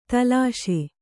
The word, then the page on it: ತಲಾಶೆ: talāśe